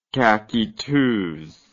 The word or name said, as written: caqueteuse